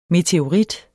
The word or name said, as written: meteorit